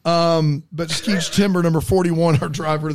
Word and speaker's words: Skeech: Not Skeets, but Skeech. Not Skeet Ulrich. – Conventional spelling of timber? – Yeah. It's Skeech, S-K-E-E-C-H, Timber, as you would spell it.